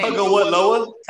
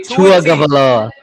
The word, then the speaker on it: Tagovailoa: Tagovailoa. – Tagovailoa something. Okay. – Tagovailoa.